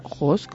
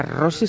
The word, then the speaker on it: Rose: y por eso ella creó la revista Ross, como le gusta decir, revista Ross, escrito R-O-S-E. Entonces son tres W, revista Ross, me está mirando, punto es, ¿verdad? ¿Lo he dicho bien? – Perfectamente.